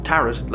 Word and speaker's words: Tarrant: Yes, they foreshadow it from the start by having her father's name be Leo Tarrant, pronounced Tarrant like parrot, but that's just a normal name, or so we think.